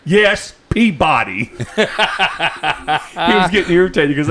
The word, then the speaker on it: Peabody: He goes, what, 6421 Peabody. I went, Peabody? – Peabody? He goes, yeah, Peabody. I said, can you spell that? He goes, P-E-A-B-O-D-Y. I said, oh, Peabody.